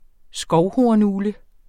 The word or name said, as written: skovhornugle